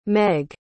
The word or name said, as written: meg